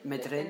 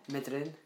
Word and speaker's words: Medryn: Say it properly. – Medrin. – In the nice way. – Medrin.